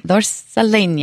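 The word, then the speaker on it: Zorzaleña: Z-O-R-Z-A-L-E-N-Y-A. – Okay. – Zorzalena. – Zorzalena. – Yes, and it's grafted with the Gordal, and because the Gordal has a very weak trunk, but the Zorzalena has a very sturdy trunk.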